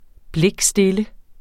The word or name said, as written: blikstille